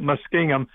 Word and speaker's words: Muskingum: That's the way I spell it. We always pronounced it Muskingum.